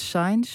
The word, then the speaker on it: Sainz: Ja, waar ik Sainz zei, is het Sainz.